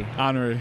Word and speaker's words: Henri: Do you pronounce it Henry or Henri? – Henri. – Henri? Okay, I thought it was French.